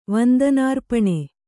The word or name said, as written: ವಂದನಾರ್ಪಣೆ